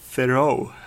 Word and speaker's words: Theroux: Eh, de är i ett mötesrum och eh, vad heter han, Justin eh, Theroux, vad heter han? – Mm. – Eh, jo men... – Ja, nu sa jag rätt. Jag köper det uttalet. Eh, exet är tyst.